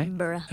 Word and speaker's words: Edinburgh: Did I say that right? – Edinburgh. – Okay, we got schooled. Laura and I got schooled on that by which one was it? Alan Cumming, yeah. – It sounds like Wilbur.